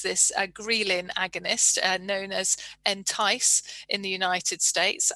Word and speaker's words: Entyce: And I may have been pronouncing some of these words incorrectly because it's not something available to me, but I'm pretty sure Entyce is pronounced as I've said it.